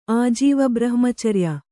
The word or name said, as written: ಆಜೀವಬ್ರಹ್ಮಚರ್ಯ